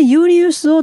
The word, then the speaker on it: Julius: そこでユーリウスを称えて、この月の名前をジュライに変えたんです。ユーリウスというのは JULIUS と書いてユーリウスと読む。これを英語読みすると J をジュと読みますのでジュリアス、だからジュライということで、 7 月ジュライはシーザーのお誕生月だったんでそうなったんですね。